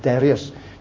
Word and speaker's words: Darius: So, but the point is that for the purpose of our study, we'll pronounce it as Darius, just as we pronounce it as Daniel.